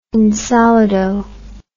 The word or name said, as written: insolido